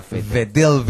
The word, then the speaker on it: Vettel: Y el campeón más joven de la historia de la Fórmula 1, con 23 años, se llama Sebastián Vettel. – Vettel, se ve con B corta, pero es alemán, así que se pronuncia Vettel.